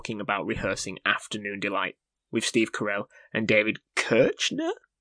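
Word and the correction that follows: Koechner: I believe it's pronounced David Keckner.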